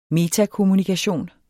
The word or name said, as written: metakommunikation